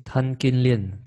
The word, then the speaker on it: Tan Kin Lian: How do you say his name? – Tan Kin Lian. Chen Jing Lian.